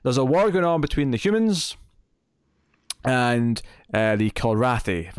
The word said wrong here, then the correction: Kilrathi: Am I saying that right? Kalrathi? – I've already forgotten what they're called. Kilrathi.